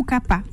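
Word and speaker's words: kapha: Ça s'écrit kafa. – Kafa et ça se prononce kafa ou kappa. Ou kappa.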